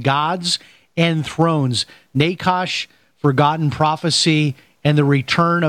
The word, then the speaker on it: Nachash: It's a, yeah, you asked how it's pronounced uh, in in the Hebrew. It'd be it would be more like Nachash, N A W Nachash.